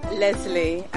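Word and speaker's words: Lesley: L-I-I-S-A. – Okay, I'm glad I asked. Your name? – Leslie, and it's L-E-S-L-E-Y.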